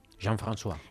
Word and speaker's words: Jean-François: Ay, qué bien lo pronuncias. ¿Puedes decirlo, por favor? – Jean-François. – Exacto.